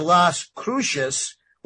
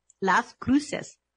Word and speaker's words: Las Cruces: It takes place in Las Cruces, which I think I said that right. Las Cruces. – No. – Anyway, Cruces. How do you say that? – Las Cruces. – Las Cruces. Okay, there you go.